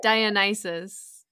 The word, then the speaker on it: Dionysus: In English, we would pronounce Dionysus.